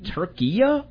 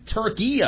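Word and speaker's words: Türkiye: Turkia, I think, is what the pronunciation is now.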